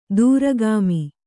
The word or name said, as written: ದ್ರಾಘಿಮ